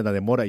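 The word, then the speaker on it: Dannemora: Voy a decir Danemora más de una vez, ya advierto, y sé que se pronuncia Danemora y además, mira que no tiene tilde,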